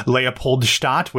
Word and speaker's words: Leopoldstadt: Macbeth is closing at the Long Acre, where we are going to have up next, starting on October 2nd, Tom Stoppard's Leopoldstadt, which I did get that pronunciation correct.